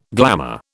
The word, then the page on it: glamour: British English